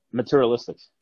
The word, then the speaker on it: materialistic: But, you know, is meritalistic. Oh my God, I'm mispronouncing. – Materialistic.